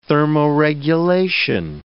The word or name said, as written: thermoregulation